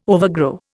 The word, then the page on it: overgrow: Indian